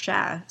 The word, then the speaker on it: chat: Yeah, it's pronounced chat, Chat, so it looks like chat.